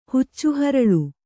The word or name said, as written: ಹುಚ್ಚುಹರಳು